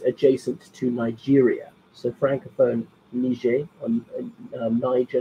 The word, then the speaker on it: Niger: So commenting is some coup d'etat in Niger, so don't mispronounce that one. It's a soft G, not a hard G, so named because the river Niger bisects it.